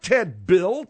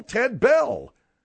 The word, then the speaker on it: Ted Bell: B-E-L-L. – But instead of, you spell B-I-L-L. – No, it ain't Ted Bill, Ted Bell.